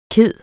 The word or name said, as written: kede